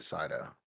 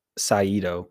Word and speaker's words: Saito: I think Ames, played by Tom Hardy, I think he pronounces it Sato. – Yeah. – The British pronunciation. – But like Cobb said Saito.